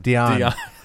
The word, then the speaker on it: Dionne: Dionne.